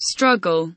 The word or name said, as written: struggle